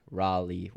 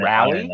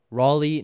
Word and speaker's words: Raleigh: I'm getting roasted for the pronunciation. Raleigh. What did I say? Raleigh? – He said rally? – Raleigh? Raleigh? Yeah, I might have said rally.